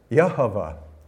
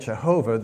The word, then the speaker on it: Yehovah: There is no J in Hebrew. It's a Y. And it would be pronounced Yehovah. And the irony of that is that we call it Jehovah, the God of who keeps his promises.